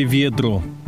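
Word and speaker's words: вёдро: Взять хотя бы славянское наречие «ведро». Если бы не ударение, то его легко было бы спутать с существительным «ведро». В ведре мы чаще всего носим воду, а вот «ведра» означает ясную погоду, во время которой выпадение воды в виде дождя не предполагается.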